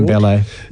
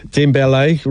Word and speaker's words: Dembele: Dembele. – Dembele rhymes with Mbappe.